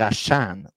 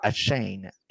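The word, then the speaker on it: Achane: Euh, les running backs, vous avez devant Ashan, il faut dire Ashan au lieu de ah! Hachan. – Ashane, c'est Ashan. – C'est Ashan. Parce que là, les, les gens du côté des Dolphins ont remarqué qu'on a trop prononcé son nom. Le gars a fait 51 points fantasy. – Ben, il y a de quoi aussi. – Fait que là, ils sont comme dit, Colin, faudrait peut-être leur dire comment prononcer le nom comme du monde. Donc, c'est pas a chain, c'est a a A Ryan Mostert, lui, euh, c'est plus ça se dit mieux.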